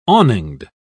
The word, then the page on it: awninged: British English